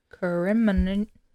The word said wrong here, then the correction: commemorate: Words I cannot pronounce. – To commemorate. – Commemorate.